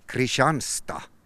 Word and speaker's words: Kristianstad: Och nu ska du iväg till Sverige och handbollskarriären fortsätter i södra Sverige, en stad som, ja, om man läser det hur det skrivs så heter den Kristianstad. Men svenskarna och ortsborna och alla svenskar egentligen kallas det, eller uttalar den som Kristianstad.